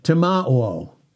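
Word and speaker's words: timao (τιμάω): It also goes into the Greek word timao. T-I-M-A-O. Long O.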